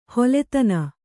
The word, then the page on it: ಹಲುದಿನು: Kannada